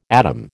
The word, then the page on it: atom: American English